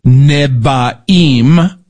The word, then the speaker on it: Nevi'im: So the name Tanakh comes from the first letters of the Hebrew words for Torah, that's law, Nevi'im, which is the prophets. It's spelled N-E-V, but kind of sounds like a B, like boy, Nevi'im, and the Ketuvim, which is the writings.